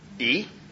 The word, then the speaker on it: iye: It's E. You can spell it if you're spelling it in English, I-Y-E.